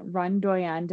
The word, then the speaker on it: RunDoyen: So he has a brand, RunDoyen. Did I pronounce that right? – That's right.